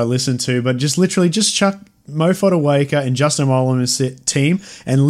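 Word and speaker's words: Moa Fotuaika: AJ Brimson, Moa Foeaker. Sorry for the pronunciations. – You've absolutely butchered that. Mo Fodawaker, mate.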